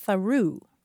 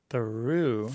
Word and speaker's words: Thoreau: She said, I taught at an Indian reservation in New Mexico, and the author named Thoreau is a town name there, but it's pronounced Tharu. – Theroux. – Theroux. So you go through Theroux. – Mm-hmm. – But you'd think it would be pronounced Theroux. – But it's spelled like the American author.